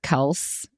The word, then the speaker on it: Kelce: dude, did you hear that Travis Kelce is it Kelce or Kelce? – Okay, we debated this.